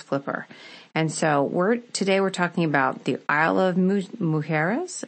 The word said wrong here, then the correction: Isla Mujeres: Am I saying that correctly? – Isla Mujeres. – Isla Mujeres.